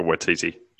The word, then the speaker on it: Waititi: Matt Berry's in it, but like a Waititi. – Yeah. Thank you, for coming in with the correct pronunciation there. But the movie was obviously great, and the TV show is excellent as well.